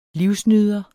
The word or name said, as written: livsnyder